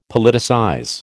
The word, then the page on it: Politicize: American English